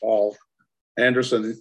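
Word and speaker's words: Poul Anderson: It's spelled where it's spelled P-O-U-L, but it's pronounced Paul Anderson.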